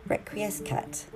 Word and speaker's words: Requiescat: And it's called Requiescat. I've said that wrong. Requiescat. There we go.